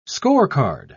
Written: scorecard

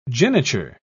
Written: geniture

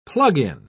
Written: plug-in

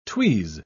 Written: tweeze

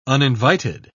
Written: uninvited

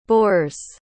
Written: bores

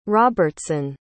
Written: robertson

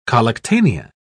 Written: collectanea

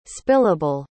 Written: spillable

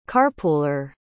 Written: carpooler

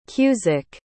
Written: cusick

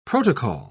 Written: protocol

